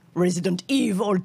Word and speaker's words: Resident Evil: Je sais que ça se prononce Resident Evil, tu vois.